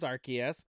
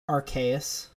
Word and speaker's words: Arceus: Um, one, are you sure it's not pronounced Arceus? It's Arceus.